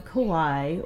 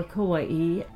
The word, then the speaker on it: Kauai: We're on Kauai, or Kauai, pronounced both ways.